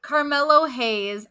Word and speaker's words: Carmelo Hayes: We have Camilo Hayes going against Giovanni Vinci for the North American. – Love, what? there is an R in that. You said Camilo.